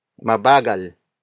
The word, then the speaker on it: mabagal: Mabagal is spelled as M-A-B-A-G-A-L.